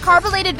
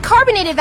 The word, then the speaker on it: carbolated: DL, I heard you mispronounced carbolated Vaseline, and you ended up saying carbonated Vaseline, and swore you were saying it right.